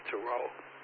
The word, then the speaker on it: Zhurong: First of all, the good thing, China has named their rover, which will eventually land on Mars, hopefully in May, called so it's actually spelled Zhurong, but it's pronounced Turo.